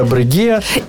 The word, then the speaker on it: Breguet: І так, не Breguet, а Breguet.